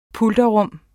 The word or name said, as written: pulterrum